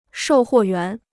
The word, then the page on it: 售货员: shòu huò yuán